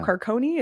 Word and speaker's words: Carcone: It's a great interview with head coach Steve Potvin and forward Michael Carcone, who corrected me to my face that it is Michael Carcone is how you say his name officially. I'm confident in that pronunciation now.